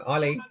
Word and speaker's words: Eileen: Eileen Way? – Eileen, yeah.